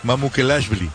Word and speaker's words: Mamukelashvili: Está también Sandro Mamukelashvili. Ándele, ahora sí me salió. – No lo pronuncias muy bien, hasta me parece que eres de allá. – Mamukelashvili.